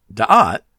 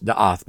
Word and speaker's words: da'at: Now, what's interesting about da'at, it can also be pronounced da'ath, by the way, is that while it is also a feminine noun, it's also used as a masculine term in Proverbs.